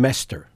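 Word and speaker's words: Meystre: And that's Pierre, how does he pronounce then his last name? – Mester. – Mester.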